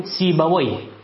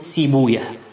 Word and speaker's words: سيبويه: فقالوا: هذه وجدناها في الأسماء فننطقها كما تنطق هذه الكلمة العربية، فنقول سيبويه ولا نقول سيبويه.